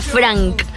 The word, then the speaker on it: Phranc: Un personaje único que se llama Frank, que se escribe P-H-R-A-N-C.